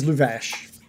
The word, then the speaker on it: Luvash: His name is Luvash. – Luvash. Like Lubega. – Exactly.